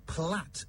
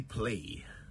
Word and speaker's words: plait: Well, it's spelled P-L-A-I-T, but it's pronounced plait in British English. The Americans, however, say plait. Some even say play.